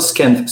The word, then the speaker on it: Schoenfeld: C'est-tu Schoenfield ou Schoenfield? Comment tu prononces ça? – Ah, moi, je prononce ça Scanfield, Scanfield, Scanfield.